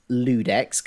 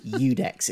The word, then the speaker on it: IUDEX: IUDEX_Phil on Twitter, which is not Ludex because it's an I. It's not IUDEX. It's pronounced Udex. It's a silent I.